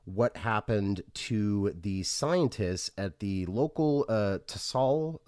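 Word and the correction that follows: Tsalal: is that how you pronounce it? – Salal.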